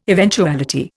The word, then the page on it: eventuality: Indian